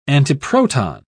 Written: antiproton

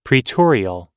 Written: praetorial

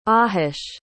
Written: ahish

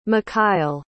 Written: mikhail

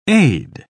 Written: aide

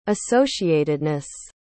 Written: associatedness

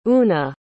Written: oona

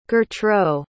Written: gertraud

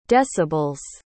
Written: decibels

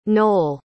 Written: noell

